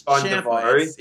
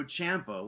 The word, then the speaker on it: Ciampa: We know it's Ciampa. It's Tommaso Ciampa, like Tampa. Ciampa.